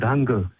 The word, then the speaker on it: Hangul: Hangul.